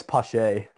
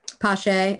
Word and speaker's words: Pache: Is it Posh, Pash, Pashy? I don't know how you say his name. – I think it's Pache. – Pache.